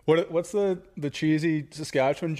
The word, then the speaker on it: Saskatchewan: You guys were born in Saskatoon, Saskatchewan.